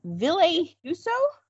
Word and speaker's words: Ville Husso: And then there's uh, Ville Husso. Mm-hmm. Did I say that right? Yeah.